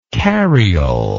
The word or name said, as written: cariole